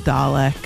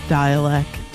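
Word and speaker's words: Dälek: You might pronounce it Dalek. It's pronounced Dialect. D-A with an umlaut. L-E-K.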